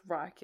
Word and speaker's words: Reich: I don't know how to say this. R-E-I-C-H. Fourth... – A Reich. – Reich? Is that how you say it? – Yeah.